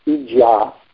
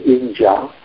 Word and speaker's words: Ijya: Although the fourth item, Ija, I-J-Y-A, with a long A, Ija, refers specifically to the direct worship of the Lord, the worship is not complete without the remaining four activities.